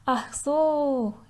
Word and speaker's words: ach so: "ach so ise anladım anlamına gelir ve az önce telaffuz ettiğim tonlamayla genelde kullanılır. Çünkü burada ha anladım, hmm, şimdi anladım gibi bir anlam vardır. Bu nedenle ah so şeklinde daha çok kullanılır ve günlük konuşmada sık kullanılan bir kalıptır.